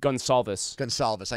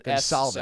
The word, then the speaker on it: Gonsalves: Or, how do you say Gonsalves? Gonsalves. I knew there was like it's a C, but it's an S. With a Z. – Yeah, it's an Gonsalves. S sound.